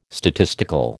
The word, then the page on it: statistical: American English